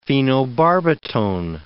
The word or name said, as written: phenobarbitone